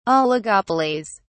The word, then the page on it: oligopolies: British English